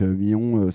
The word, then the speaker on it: Mion: Donc Millon, ça s'écrit M-I-O-N tout simplement. Et Alain comme Alain, le Alain de ta famille.